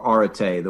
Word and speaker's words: arete: You know, excellence, arete, or arete, the way classicists pronounce it.